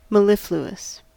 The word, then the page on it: mellifluous: American English